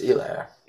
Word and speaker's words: Helaire: Is it Helaire? – Yeah, that's how it's Helaire.